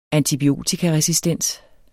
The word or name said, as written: antibiotikaresistens